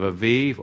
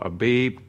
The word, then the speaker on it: Aviv: the Hebrew month of Aviv or Abib, whether you pronounce that as a bet or a vav, you know, Aviv, coming up, and then we have Shavuot and, of course, Sukkot.